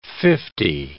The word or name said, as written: fifty